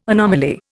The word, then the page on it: anomaly: Indian